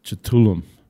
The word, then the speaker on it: Cthulhu: It's like K H U T U L U mm-hmm. Okay. Right. I know it's hard. I, I, had to like Google the pronunciation. You know, I was like, I was like Cthulhu. It was almost like almost like a like a ch sound, I guess. Cthulhu. Yeah, it's K H though.